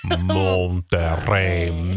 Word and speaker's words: Monterrey: I grew up in Monterrey, Mexico. – Did you hear that? – I say it again. – Monterrey, Mexico. – Monterrey, Mexico. – You maybe could slow it down a bit, too. – Monterrey, Mexico.